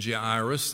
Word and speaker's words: Jairus: Now, I've never really felt comfortable pronouncing the name Jairus. That doesn't sound right to me, but the Interpreter's Dictionary of the Bible says that's how you're supposed to say it.